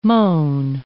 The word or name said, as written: mown